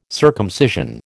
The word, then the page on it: circumcision: American English